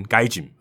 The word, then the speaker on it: gaijin: 他英文拼的是 G A I J I N，该井啊。